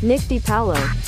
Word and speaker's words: DiPaolo: How do you pronounce his name? Good Lord, now I gotta look this up. I always botch these. Pronounce Nick DiPaolo. – Nick DiPaolo. – DiPaolo? – Nick DiPaolo. – That's not the way it reads, but...